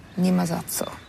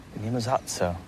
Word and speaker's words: Nie ma za co: Nie ma za co. – Nie ma za co.